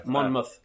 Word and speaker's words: Monmouth: No, because it's spelled mouth, but I think it's pronounced Muth. – Yeah. – Monmouth.